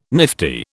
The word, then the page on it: nifty: British English